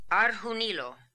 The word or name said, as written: Arhoonilo